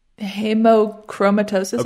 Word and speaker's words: hemochromatosis: So do you want to talk to us about, I can't even pronounce the word, word? Hemochromatosis? – Hemochromatosis. Okay. I think you said it right.